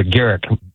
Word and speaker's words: Garrick: That's a Garrick with a hard G.